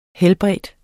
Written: helbrede

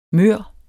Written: mør

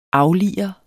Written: aflire

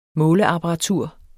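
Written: MÅLEAPPARATUR

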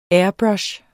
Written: airbrush

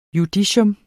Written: JUDICIETS

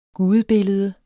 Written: gudebillede

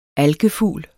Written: ALKEFUGL

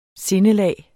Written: sindelag

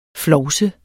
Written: flovse

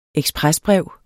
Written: EKSPRESBREV

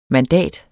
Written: mandat

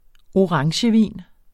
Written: orangevin

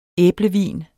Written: æblevin

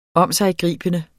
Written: omsiggribende

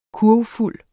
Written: kurvfuld

